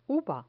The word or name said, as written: uva